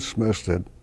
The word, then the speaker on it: Smestad: Erling Smestad.